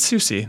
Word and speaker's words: SUSE: I think it's SUSE. That's how they said it on stage.